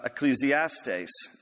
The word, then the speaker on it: Ecclesiastes: And so, for those of you who like to take notes, Ecclesiastes is from a Greek word spelled pretty similarly, just with K's instead of C's, uh, and pronounced uh, Ecclesiastes, Ecclesiastes, meaning one who calls or gathers the ecclesia now, the ecclesia is just another Greek word that means church or means assembly.